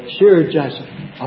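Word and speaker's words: Shear-jashub: There was going to be Shir Jashub. Is that how you pronounce that? I keep saying it different every time. That's close. Shir Jashub.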